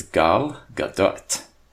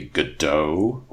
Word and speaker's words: Gadot: So the actress's name, pronounced properly, is Gal Gadot. And um, as long as you don't say Godot or Gadot, which I keep hearing, those are both terrible.